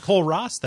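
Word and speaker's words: Kole Ross: I'm Cole Ross.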